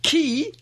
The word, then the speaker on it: quay: As a member in the US Navy, key, pronounced or spelt Q-U-A-Y, was pronounced Key, as in Key, along the waterfront.